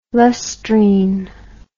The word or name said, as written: lustrine